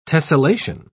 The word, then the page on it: tessellation: British English